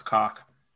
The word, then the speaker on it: Koch: Uh, that's name's actually pronounced cock. – Oh, I was wondering if it is because that's the German K O C H, right? – Yeah.